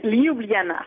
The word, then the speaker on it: Ljubljana: Ljubljana? – Ljubljana. – Ah, Ljubljana. Bon. – Ouais, les J se prononcent avec des I finalement.